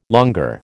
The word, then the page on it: longueur: American English